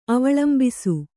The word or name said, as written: ಅವಳಂಬಿಸು